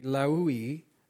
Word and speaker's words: Levi: Named Levi, the Hebrew pronunciation would be Lehui, which is where the Levites, the ones that were called to be priests, maybe he was of that.